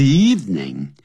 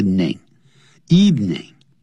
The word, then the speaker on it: evening: In the evening, 不要念成 evening, 啊就是两个音节而已 ,eve, 除夕 ,eve, 加个 name,evening, 更是在 with their parents, 跟爸妈在一起。